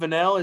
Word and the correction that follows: Fennell: is that how you say her name? Emerald Vanell. She's Fennell. – Fennell. It's Fennell, a fascinating.